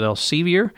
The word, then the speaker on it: Elsevier: Okay, and you approved a sole source bid for a 3-year contract with, they pronounce it, Elsevier, for the purchase of Evolve 360 nursing software.